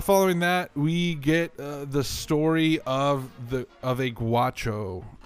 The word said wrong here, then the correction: gaucho: I said that wrong again, didn't I? – Yeah, the gaucho. – Gaucho. I don't know why I keep saying guacho.